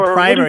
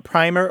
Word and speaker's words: primer: Yeah, primer, yeah. The word's got two pronunciations. So a primer or a primer, P-R-I-M-E-R, is a book that teaches you something.